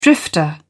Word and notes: drifter: British English

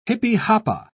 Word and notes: jipijapa: British English

